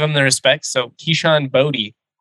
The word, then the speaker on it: Boutte: And then Kayshawn, I think we're calling him Boutte, but I actually prepared on this. I heard him, I think he pronounces it Boutte.